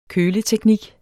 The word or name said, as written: køleteknik